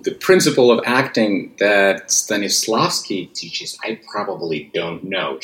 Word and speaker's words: Stanislavski: How do you pronounce the name? – Stanislavski. – Stanislavski. – Yeah, right? You nailed it. Yeah, Stanislavski.